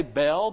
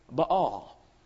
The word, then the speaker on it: Baal: We always say Baal, but it's a double A. It's Baal. Say it, Baal, if you want to.